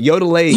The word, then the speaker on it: Yodelady: Yodelady.